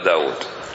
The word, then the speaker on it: داود: داود الله رازق واوين، واوين، لكن ما في أحد ينطق داود بواو واحدة، حتى لو ما يعرفه من قبل أول مرة يشوفه على طول يقول له داود داود.